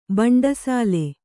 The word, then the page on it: ಬಂಡಸಾಲೆ: baṇḍasāle; Kannada